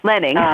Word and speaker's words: Lehning: Lehning.